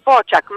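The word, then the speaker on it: Fočak: Fokak, ho detto bene il cognome? – Un po' diverso, Pociak, ma voi non avete questo carattere nella lingua italiana. – Infatti, perché c'è una piccola V sopra la C.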